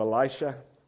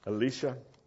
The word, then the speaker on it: Elisha: And Elisha, can you say that? Instead of Elisha, Elisha.